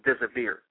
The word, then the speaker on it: désir: That word de- deserve comes from an old French word, which which is pronounced désir, and the word désir means to serve devotedly.